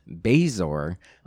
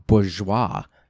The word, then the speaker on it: bezoar: In the introduction to Snape, he asks Harry where he could find what I always read as Bezoar, but you know, it helps cure the poisons. But it is actually pronounced, and at least in the audible version, bourgeois. I guess it's a French word, and I had no idea.